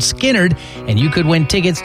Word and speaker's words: Skynyrd: The gym teacher's name was Leonard Skinnerd, but they changed the way they spelled it, so I'll spell it for you because Skinnerd is your key word that could win you tickets to the concert. It's SKYNYRD.